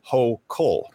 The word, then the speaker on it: Hochul: The newly inaugurated governor of New York, Kathy Hochul, actually, I believe it's pronounced Hochul.